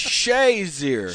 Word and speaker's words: Shazier: How do you pronounce his name? – It's Shazier.